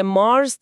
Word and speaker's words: مارس: یه چیزی که همیشه بر من جالب بوده اینه که اس آخر مارس تلفظ میشه.